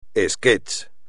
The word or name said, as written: esquetch